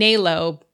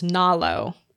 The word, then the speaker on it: Nalo: So I did mispronounce her name last time, and someone in the Goodreads thread corrected me. I was saying Nalo, but I believe it's Nalo.